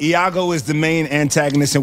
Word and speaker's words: Iago: It's no. Iago. It's an I.